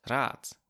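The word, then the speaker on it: Rad: the words for advice and wheel are both pronounced rat with a t. Even though rat as in advice is spelled with a t, and rat as in wheel is spelled with a d.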